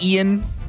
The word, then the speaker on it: Eian: Am I saying that correctly? – Pretty sure it's just Ian. Ian Elmer. – E-I-A-N Elmer.